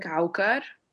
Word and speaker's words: Aucar: I mean, it's pronounced in Arabic, Aucar. – Aucar? – Aucar. – Aucar. Okay. Whatever you say in English is correct.